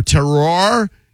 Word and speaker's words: Terroir: I never know how to say it. Bar-terror. T-E-R-R-O-I-R. Terror. Terroir, Bar Terroir, Bar Terroir.